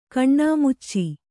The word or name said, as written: ಕಣ್ಣಾಮುಚ್ಚಿ